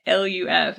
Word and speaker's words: Magaluf: So in the resort town of Magaluf, I'm sure I'm saying that wrong. – Magaluf. – It's Magaluf, and I do know that it's like a resort town that is on the island of Mallorca, so it's a little village within.